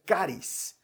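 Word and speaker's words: caris (χάρις): Don es una palabra que en, en griego se pronuncia caris y que literalmente significa regalo.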